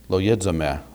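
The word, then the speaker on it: loyizome (λογίζομαι): Now, in my little parenthesis there, you see the Greek word loyizome, that's how it's pronounced in modern Greek, doesn't really make any difference.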